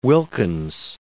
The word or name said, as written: Wilkins